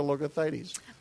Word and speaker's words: Logothetis: Let me go around the table and let everybody introduce themselves, and we're going to start with Georgia Logothetis. I want to learn say your name the way you say it. – Sure, Logotheti. – Logothetis. – Very good. – Like a There's no S.